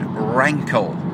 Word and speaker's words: rankle: And I picked out the word rankle. R-A-N-K-L-E. Rhymes with ankle. Kind of half rhyme with anchor. So rankle.